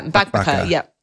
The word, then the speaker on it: Mbakbaka: There's another recipe and boy, I'm not confident that I will pronounce this correctly, but it's the one pan Mbakbaka. Yeah, Mbakbaka. Yeah, that's it. You did well.